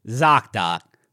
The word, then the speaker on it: ZocDoc: This actually says ZocDoc, which rhymes with Shock Doc.